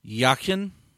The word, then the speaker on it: Yakin: Second half, our upcoming audio guest, and I'm going to try and say this. The Rowdies were tweeting it, the pronunciation guide, and I know you talked to him. Yakin, does that sound right? – That sounded much better than the way I did it this afternoon.